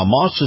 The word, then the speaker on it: Maus: Now, Moss is spelled M-A-U-S, and Moss Acura is the latest in the addition to the Moss Family Automotive Group on North Florida Avenue in Tampa.